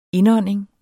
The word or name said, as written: indånding